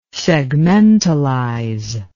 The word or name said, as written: segmentalize